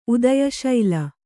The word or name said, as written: ಉದಯಶೈಲ